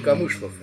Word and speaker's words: Камышлов: Там ударение ставится на последний, но все-таки не Камышлов, я думал, что... – Да, Камышлов. Ну вот, батюшка мой по железнодорожному ведомству служил, и ему, так сказать, пришлось поехать в Тюмень.